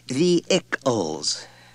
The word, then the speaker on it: vehicles: She said brightly when he had finished reading, it's pronounced vehicles, Richard. No H. Accent on the first syllable.